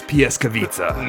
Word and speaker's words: Pljeskavica: We're so dedicated to the sponsor read that we we listen to the pronunciation of that food item on YouTube before we record this episode. And we're so confident in it, we'll say it again. Bjelica's Pljeskavica. Nailed it.